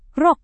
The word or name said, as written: rok